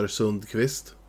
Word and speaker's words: Sundqvist: So you say Oscar Sundqvist, his Swedish pronunciation would be Oscar Sundqvist.